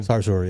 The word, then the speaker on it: Sarsour: Is it Sarsour? Is that... Did I say it right? – Sarsour, yeah.